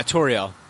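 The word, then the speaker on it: Tauriel: Yeah, Toriel.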